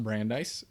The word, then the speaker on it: Brandeis: Talk about his path to Brandeis, and it is pronounced Brandeis. So I learned that the hard way after Supreme Court Justice, apparently.